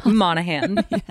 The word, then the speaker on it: Monaghan: Monaghan. Silent G.